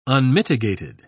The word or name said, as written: unmitigated